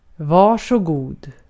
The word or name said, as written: varsågod